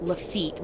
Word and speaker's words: Lafitte: Some say it was first built by the pirate Jean Lafayette. – Really? I don't know. And also, it's pronounced Lafitte, not Lafayette.